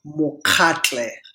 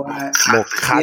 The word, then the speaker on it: Mokatle: My first name is One, and my last name is Mokatle. So Mokatle. Yeah, you gotta really dig deep back of your throat to get that ka sound,